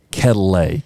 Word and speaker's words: Quetelet: It's French. – But it's spelled Quetelet. – Yeah. How do you think an average American would say that, not knowing any French? – I would have said Quetelet if I hadn't have looked it up.